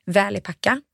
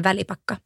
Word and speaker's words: Välipakka: Då skulle jag säga att som svenskt uttal så säger de flesta Välipacka. – Ja. – Och som finskt uttal så säger man Välipakka.